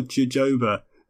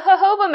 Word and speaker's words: jojoba: But jojoba makes a little bit of sense because it's a Mexican plant. And in Mexico, J is said with an H sound.